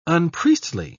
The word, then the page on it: unpriestly: British English